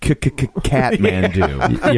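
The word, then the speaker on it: Kathmandu: I believe it's pronounced Katmandu.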